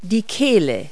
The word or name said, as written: throat